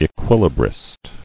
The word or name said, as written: Equilibrist